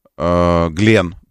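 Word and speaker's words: Гленн: Согласно трансляциям сайтов из ПСН, мегаракета Нью-Глин, Глен, правильно говорить, ну, в честь астронавта, компания Blue Origin взорвалась во время испытаний на стартовой площадке на мысе Канаверал.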